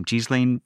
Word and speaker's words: Ghislaine: And the like incredible preferential treatment that, I always forget how to pronounce her name, Ghislaine Maxwell, thank you, that she's getting like waited on hand and foot in prison now.